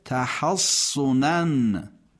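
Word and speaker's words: تحصنا: در ادامه به تشدید حرف صاد در کلمه تحصنن، تحصنن، حرف صاد تشدید داره و یه ترکیب ستایی، عرض الحیات الدنیا، وقفش چیه؟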